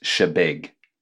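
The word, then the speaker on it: Chebeague: Yeah, I read the article yesterday, and in the first sentence, it says it's pronounced Shebeeg. – Shebeeg, all right. – Yeah, so we always had that wrong.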